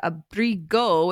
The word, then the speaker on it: abrigo: Abrigo, which I just found out has a hard G, is coat.